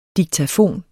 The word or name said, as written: diktafon